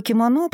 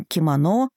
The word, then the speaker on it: кимоно: Единственное, что, например, в русском языке ударение сместилось к последнему слогу, и мы говорим «кимоно», в то время как японцы произносят это слово «кимоно».